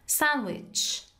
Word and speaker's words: sandwich: sandwich sandwich یا sandwich sandwich دی تلفظ نمیشه.